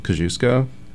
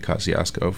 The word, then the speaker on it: Kosciuszko: It's like, I live in Brooklyn, and I live off the Kosciuszko J-stop, and I think it's Polish, and it's technically pronounced Kosciuszko, but mm-hmm. The people in that neighborhood have been pronouncing it Kosciuszko for, like, decades, and that's, you know...